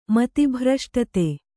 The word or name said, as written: ಮತಿಭ್ರಷ್ಟತೆ